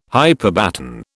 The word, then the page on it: hyperbaton: British English